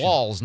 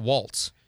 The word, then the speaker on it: Walz: It's Walz, not Waltz.